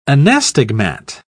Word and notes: anastigmat: British English

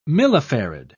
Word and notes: millifarad: British English

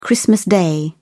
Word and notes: christmas day: British English

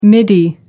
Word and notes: midi: American English

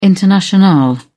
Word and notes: internationale: British English

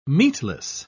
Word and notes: meatless: British English

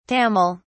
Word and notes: tamil: British English

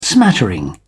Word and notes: smattering: British English

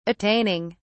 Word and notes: attaining: British English

